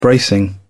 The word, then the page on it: bracing: British English